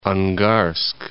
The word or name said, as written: Angarsk